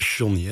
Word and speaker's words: Johnny: Dat Johnny Jordaan is natuurlijk een grote held. – Ik word er net op gewezen inderdaad door mijn regisseur dat het Johnny is.